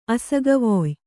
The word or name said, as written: ಅಸಗವೊಯ್